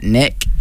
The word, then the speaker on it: Nick: You're looking at Nick. – You're looking host, right at Nick. – How do you spell that? – N-I-C-K.